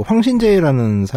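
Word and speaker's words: 황신제 (黃信介): 그 황신제라는 사람이 있습니다. 1928년생인데 황신제. 예. 음. 우리나라식 발음으로는 황신개죠.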